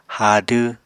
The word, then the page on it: haadw: Apatani